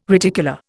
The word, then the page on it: reticula: Indian